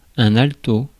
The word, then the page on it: alto: France; [al.tɔ]